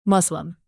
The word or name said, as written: muslim